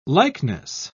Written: likeness

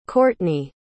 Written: kourtney

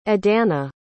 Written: edana

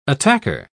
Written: attacker

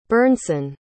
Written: burnson

